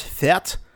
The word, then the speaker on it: fete: Did I say that right? – Fete. Yeah, I mean, I've just been saying fate because that's how they pronounce it in the town.